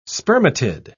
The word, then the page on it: spermatid: British English